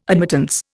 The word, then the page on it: admittance: Indian